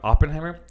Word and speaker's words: Oppenheimer: I might be saying that wrong. – Oppenheimer.